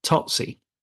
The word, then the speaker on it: Tsotsi: It looks like Totsi. It's spelt T-S-O-T-S-I.